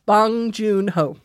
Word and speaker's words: Bong Joon-ho: It's pronounced the way it's spelled. – Bong Joon-ho.